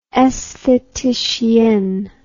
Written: aestheticienne

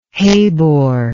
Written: Habor

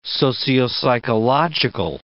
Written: sociopsychological

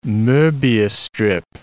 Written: Mobius strip

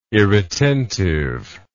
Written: irretentive

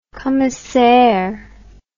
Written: commissaire

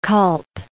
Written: Kalb

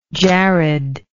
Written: jared

